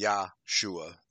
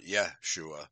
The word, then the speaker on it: Yahshua: But I think the correct pronunciation is Yahshua, not Yeshua, as they imply here.